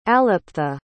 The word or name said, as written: aliptha